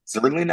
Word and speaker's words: Zuerlein: Like, I believe it's Greg Zuerlein. Zuerlein, I believe is his name. That's how you pronounce it.